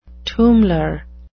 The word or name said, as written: tummler